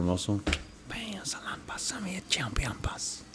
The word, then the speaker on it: chinampas: Chinampas. rhymes with Salonpas.